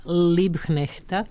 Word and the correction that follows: Liebknechta: nisam dobro izgovorila, Liebknechta, njenog saborca.